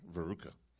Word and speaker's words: Veruca: Veruca.